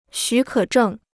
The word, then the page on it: 许可证: xǔ kě zhèng